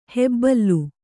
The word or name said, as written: ಹೆಬ್ಬುಲಿ